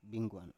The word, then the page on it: bingguan: Biak